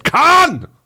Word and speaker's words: Khan: Muss man eigentlich immer dazu sagen, weil der Name wird natürlich korrekt Khan ausgesprochen. – Ja, das stimmt. Ja, also, und nicht wie Oli Khan, sondern H und A andersrum.